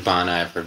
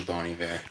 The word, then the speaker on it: Bon Iver: So he signed a letter to his friend, Good Winter, and just it looks like Bon Iver, but it's actually Bon Iver.